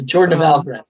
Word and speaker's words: Jordan of Algoran: It's called, the Jordan of Algoran series.